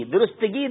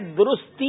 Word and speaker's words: درستی: ہم کہتے ہیں درستگی. درستگی درست نہیں ہے، درستی یہ درست ہے.